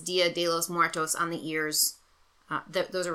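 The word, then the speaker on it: Día de los Muertos: Dia de los Muertos? – Dia de los Muertos.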